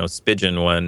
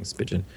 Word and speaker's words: Spigen: I don't think it's validated, because again, go get a $10, you know, Spigen, I think is how they say it. I keep saying Spigen. Spigen. – I kind of like it that way. Let's call it Spigen. – I don't think it's like a pigeon.